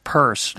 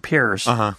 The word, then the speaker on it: Peirce: But anyway, so pragmatism, I have to confess, other than the fact that I know that it's pronounced purse, not Pierce, I don't know a lot about I haven't read a lot of Peirce, actually.